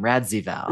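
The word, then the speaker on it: Radziwill: And Luann pronounces Carol's last name Radzivel, right? – Which apparently is the real pronunciation.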